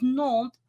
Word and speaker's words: Nantes: We kind of settled that Nantes was the, because it would be Nantes if it was ER, but it's ES. – Right. – So it's Nantes, and you wouldn't pronounce the S unless the next word had a vowel or something like that.